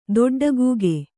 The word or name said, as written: ದೊಡ್ಡಗೂಗಿ